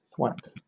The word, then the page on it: thwack: British English